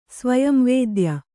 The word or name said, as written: ಸ್ವಯಂವೇದ್ಯ